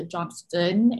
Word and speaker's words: Johnston: People think that Louisa Johnson is still in the house, Louisa Johnston, if you're gonna pronounce the T.